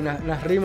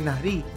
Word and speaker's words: Nasri: Lo pronuncié bien, ¿no? Ustedes que tienen tema de acento. – Sí, sí, Nasri me gustó. – Nasri, creo que es Nasri.